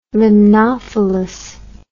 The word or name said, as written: limnophilous